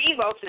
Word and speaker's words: Evox: It's spelled E-V-O-X, but it's pronounced Evokes, as is invoking something.